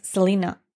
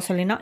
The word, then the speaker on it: Selena: la pronunciación correcta de su nombre es Selena al ser de Estados Unidos, pero aquí de toda la vida se la ha llamado Selena y ya está.